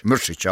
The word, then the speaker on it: Mrsić: Är det Mrsić? – Mrsić, ja.